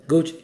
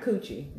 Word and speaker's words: Gucci: It would have been pronounce that correctly. – Gucci. – Gucci. Not coochie. Gucci.